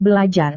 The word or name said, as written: belajar